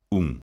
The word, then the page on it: un: lingua galega